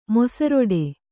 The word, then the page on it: ಮೊಸರೊಡೆ: mosaroḍe; kannada